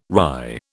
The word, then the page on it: wry: British English